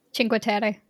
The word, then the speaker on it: Cinque Terre: So we're going to try to knock off Florence, Pisa, Cinque Terre. How'd I pronounce that? – Cinque Terre.